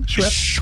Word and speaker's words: Schweppes: Schweppes.